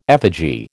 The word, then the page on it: effigy: American English